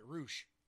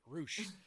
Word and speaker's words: Rush: Rush versus Dragon Lee. Sorry, Roosh. They pronounce it Roosh.